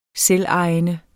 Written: selvejende

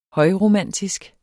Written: højromantisk

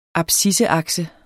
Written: abscisseakse

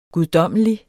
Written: guddommelig

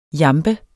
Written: jambe

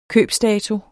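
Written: købsdatoen

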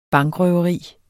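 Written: bankrøveri

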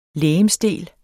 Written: legemsdel

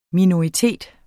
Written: minoritet

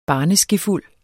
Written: barneskefuld

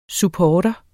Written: supportere

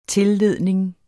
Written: tilledning

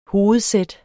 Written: hovedsæt

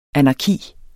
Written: anarki

